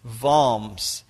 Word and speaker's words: Worms: That's actually spelt Worms, but it's pronounced Worms.